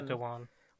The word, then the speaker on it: Dhawan: It brought back The Master, played by Sacha Dhawan, I want to say. I always mispronounce his surname. – Yeah, Sacha Dhawan.